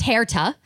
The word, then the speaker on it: Herta: Okay, it's spelled H-E-R-T-A and pronounced Hair-ta, and then W-A-R-E.